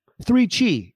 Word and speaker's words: 3Chi: 3Chi, it rhymes, is the industry leader in Delta-8 THC products.